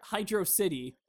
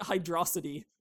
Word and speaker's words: Hydro City: One of his top 10 games was Sonic 3, and in Sonic 3, in the level Hydro City, which I've always yeah. I've always pronounced it Hydrosity because I didn't get it because I'm fucking stupid.